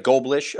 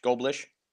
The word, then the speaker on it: Goldblish: It's like Goldblish or like Goldfish. Goldblish.